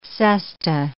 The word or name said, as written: cesta